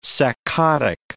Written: saccadic

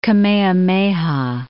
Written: Kamehameha I

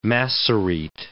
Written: Masorete

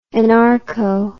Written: anarcho

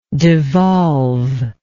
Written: devolvement

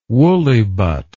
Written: woollybutt

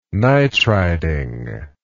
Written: nitriding

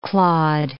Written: clawed

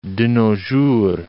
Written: de nos jours

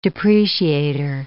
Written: depreciator

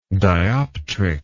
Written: dioptrics

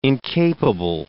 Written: incapable

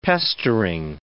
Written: pestering

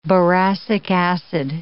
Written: boracic acid

